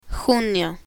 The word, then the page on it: junio: spanish